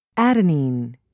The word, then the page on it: adenine: American English